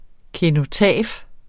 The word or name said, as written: kenotaf